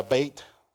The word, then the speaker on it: Beit: And Beth, every time you hear the Beit, that's how you say it in Hebrew, prefix, it's usually with regards to a house.